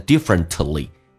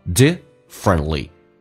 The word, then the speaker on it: differently: T 跟 L 在一起的时候 T 可以不发音，所以不发成 differently, T 就可以不发音，叫 differently。